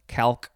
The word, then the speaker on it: calque: And then this is just from the Turkish word CalPac, K-A-L-P-A-K. So maybe we'll find a picture of this CalPac. Next is the last word. It is just pronounced calc. It is spelled C-A-L-Q-U-E, calc, noun from 1937.